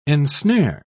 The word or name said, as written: ensnare